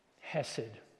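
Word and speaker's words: Hesed (חסד): There should have been rejection, but that was overturned by a thing called Hesed. Yeah, I said that right. I've struggled to say this word right.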